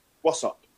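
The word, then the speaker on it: Worksop: That coupled with his accent as well, because he came from a place called What's Up, yeah, as we as we would call it, Works Up, but he's yeah. pronounced it What's Up.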